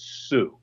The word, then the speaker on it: Sault: By the way, Marshall, it's Sault Ste. Marie, not Sault Ste. Marie. You got to first know that. It's spelled S-A-U-L-T, but it's Sue.